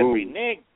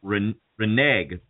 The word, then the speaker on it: renege: And I said, renege? Joe, no, you didn't. – You know, that is the proper pronunciation, the way that you just said it, even though it sounds racist. I always would say renege, but the way you said it, I learned, is the proper pronunciation.